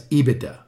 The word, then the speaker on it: EBITDA: That's just to be clear, that's EBITDA, right? – EBITDA. Did I say it wrong? – No, you said it good.